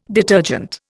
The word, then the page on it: detergent: Indian